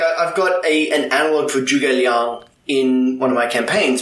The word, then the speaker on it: Zhuge Liang: I've heard it pronounced Zhuge and Zhuge Liang, like more of a J than a Zh sound. It's always going to be a J. – Okay. – With awesome. The ZH.